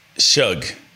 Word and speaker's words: Shough: Pronounce this word. S-H-O-U-G-H. – S-H-S-H-O-U. Shug. – Yep, there we go.